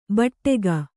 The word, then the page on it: ಭಾಡಿಗೆ: bhāḍige